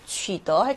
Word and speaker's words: 取得: 取得 할 때 얘는 데이가 아니라 더라고 읽힙니다.